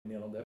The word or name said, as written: neerlandais